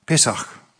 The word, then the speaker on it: Pesach: Og så løs bøy det første postene innført, og postjer, ta hebraisk året, til Pekesh, unnskylda, Pesach, jeg uttaler seg 100%, men cirka det.